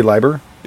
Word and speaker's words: Leiber: How do you spell it? – L-E-I-B-E-R. – Wouldn't it be Leiber? – It's German, yeah.